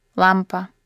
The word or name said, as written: lamp